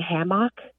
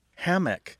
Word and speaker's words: hammock: Well, one thing I'm hearing right away is that there's a difference in stress. Hammock tends to have a stress on the first syllable, where hammock tends to have a stress on the second syllable.